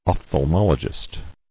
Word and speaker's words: ophthalmologist: Ophthalmologist.